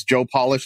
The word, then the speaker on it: Polish: My name is Joe Polish, like you said, like nail polish or shoe polish, spelled the same way as Polish, but I pronounce it polish.